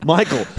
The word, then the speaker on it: Michael: Michael Sarnowski. I was so thrown by Sarnowski that I completely forgot that I have to pronounce Michael correctly.